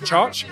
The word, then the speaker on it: Txotx: I think is fantastic, and basically anything from Twin Islands and also the Choch importers as well. That's the TXOTX guys. It's pronounced Chotch.